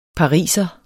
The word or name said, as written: pariser